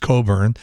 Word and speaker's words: Cockburn: Did you know that Olivia Wilde's legal name is Olivia Jane Coburn, spelled C-O-C-K-B-U-R-N, but that's usually pronounced Coburn.